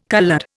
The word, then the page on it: coloured: Indian